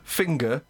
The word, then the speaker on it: finger: But you don't need to pronounce the G's that hard. – Yeah. – Singing. Ging. – Yep. – In some words, yes. Finger. – Yes, it's essential. – But it's not finger ing.